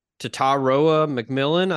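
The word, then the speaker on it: Tetairoa McMillan: I'm going to butcher his name, but Tataroa McMillan, I think I might have got it. – I think you got it.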